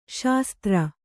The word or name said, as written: ಸುಸ್ಥಿರ